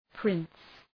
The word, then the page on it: prince: prıns